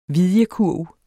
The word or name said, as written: vidjekurv